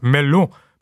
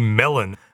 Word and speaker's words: Melun: Within three days, he had left Paris and retreated to a place that you assume in French is pronounced Melun, but in Australian English would be Melon, about 40 kilometres southeast of the capital.